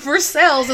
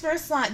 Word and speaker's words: Versailles: It's Louisville, it's Versailles. Go to France.